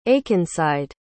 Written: akenside

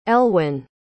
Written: elwin

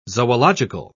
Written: zoological

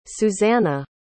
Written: suzannah

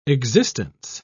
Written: existence

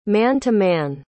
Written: man-to-man

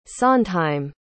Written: sondheim

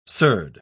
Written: surd

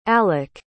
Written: alek

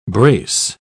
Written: brace

